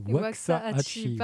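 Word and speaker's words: Waxahatchee: Je crois que la prononciation exacte, est Wakaachi.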